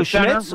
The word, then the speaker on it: Schmitz: My second point was for John Michael Schmidt Riley, or I forget how you say it. – John Michael Schmitz or Jordan Riley? Yeah, John Michael Schmitz.